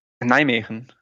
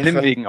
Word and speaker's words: Nijmegen: Nijmegen. – Nijmegen. Oder Nimwegen auf Deutsch. In Deutschland sagt man, glaube ich, eigentlich Nimwegen.